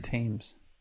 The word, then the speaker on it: Thames: No, they had Thames for a while. – That's the Thames. – Thames. – Thames. Thames. They pronounce it Thames.